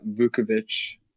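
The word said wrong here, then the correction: Vučević: And I butchered Vucevic's name.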